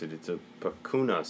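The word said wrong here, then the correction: picanha: steak. – I looked it up. It's a pecana.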